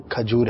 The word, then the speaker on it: کھجور: تلفظ تو ہمارے ہاں بہت زیادہ غلط بولا جاتا ہے, اور عموماً جو ہے ایک بڑی تعداد ہے جو کھجور پیش کے ساتھ استعمال کرتے ہیں، لیکن درست جو اس کا تلفظ ہے وہ کھجور ہے زبر کے ساتھ.